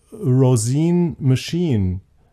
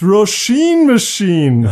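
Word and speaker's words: Róisín Machine: Seit ich mal ein Interview hatte mit Roisin Murphy und ich ihr am Telefon gesagt habe, why is your album called Roisin Machine? Da meinte sie, it's Roisin Machine.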